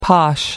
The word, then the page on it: posh: American English